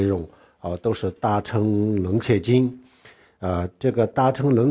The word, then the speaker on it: 楞伽: 然后呢这个大乘楞伽经楞伽就是它的准确的读音就是楞伽。